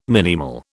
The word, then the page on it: minimal: British English